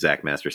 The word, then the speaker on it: Xakkmaster: You can find me on the aforementioned Critiquer at my username is Zach Master, spelled with an X, XAKKMASTER, where I post my short movie reviews.